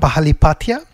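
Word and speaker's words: Palihapitiya: So I would pronounce it as Chamath Pahalipatia. – It's Pahalipatia. Is that close? – Pahalipatia. And it's a name of Sri Lankan origin.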